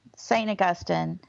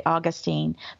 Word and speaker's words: Augustine: Most of the time, we're talking about, St. Augustine, and that's how I like to pronounce it. But really there's no, you don't have to pronounce it that way. You can also say Augustine.